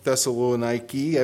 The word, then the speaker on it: Thessaloniki: It's spelled Thessaloniki, I believe. It ends with an I instead of an A, and it's a K-I instead of C-A, like the scripture.